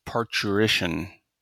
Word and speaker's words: parturition: And I think, and the pronunciation, if I'm recalling how this works, is almost like a ch in there, so parturition.